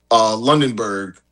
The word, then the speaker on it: Lundberg: I mean, like Lundberg, that's how you say his name.